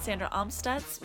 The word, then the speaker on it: Sandra Amstutz: All of my social media handles are at Sandra Amstutz.